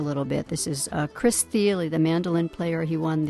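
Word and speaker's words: Thile: Thiele.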